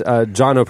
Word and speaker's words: Jono: He said it's Jono, pronounced like Bono. He's from South Africa.